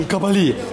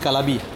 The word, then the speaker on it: Kabali: Kabali. Yesterday I told Deva and I pronounced it Kalabi.